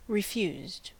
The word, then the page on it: refused: American English